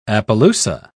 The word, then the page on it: appaloosa: British English